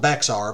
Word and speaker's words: Bexar: The six-day offensive, which became known as the Siege of Bexar, B-E-X-A-R, so if you go to look it up, it's spelled Bexar, but it's pronounced Bexar, was an ultimately successful military engagement for the Texan army, but not one without loss.